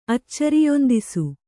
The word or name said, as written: ಅಚ್ಚರಿಯೊಂದಿಸು